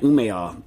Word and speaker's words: Umeå: Okay, first in from Bjorn in Sweden in Umeå, I guess is how you pronounce the name, right? Umeå, because the A with the little dot over the top is aw.